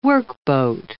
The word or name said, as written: workboat